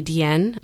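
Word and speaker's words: Diane: Yeah, so Nicole Kidman is supposed to be Deanne. Okay, it's spelled Diana, but you know, these New Yorkers.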